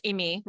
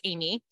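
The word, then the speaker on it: Aimee: In France, it's pronounced Amy, right? But I was born in the United States. And so it's Amy. – Amy. – And it's spelled the French way. So A-I-M-E-E. I respond to both.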